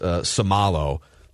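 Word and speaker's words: Seumalo: And then Isaac is pronounced Somalo.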